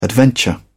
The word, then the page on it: adventure: British English